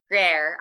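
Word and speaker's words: Gruyère: Gruyere.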